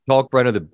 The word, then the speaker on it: Kalkbrenner: And Shireman, the transfer, Brenner, I always get his name wrong. – Kalkbrenner, he's got such great hands.